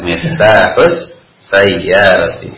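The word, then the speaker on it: miftahus sayyarati (مفتاح السيارة): Aina miftahul sayyarati. – Aina miftahus sayyarati.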